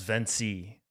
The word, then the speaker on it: Vency: I for one, didn't know it was Vency. I was pronouncing it Venci this whole time. So. – No, the minute I read it, I was like, Venci, like Conquer, like you said earlier, Conquer, or...